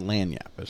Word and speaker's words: Lagniappe: And if you read it, we had to ask Google how to say it. But it's Louisiana Lagniappe is how you pronounce it, is in the name of the restaurant.